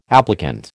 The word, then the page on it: applicant: American English